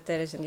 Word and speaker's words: Терезин: Э, Терезин. Ударение правильно. Терезин.